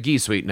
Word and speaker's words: G Suite: Is it G Suite or is it G Suite now? – No, it's a soft G in G Suite.